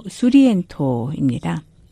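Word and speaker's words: Surriento: 제목의 소렌토는 이탈리아식 발음으로 수리엔토입니다.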